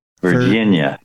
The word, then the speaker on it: Virginia: There's emphasis on the gin. Virginia.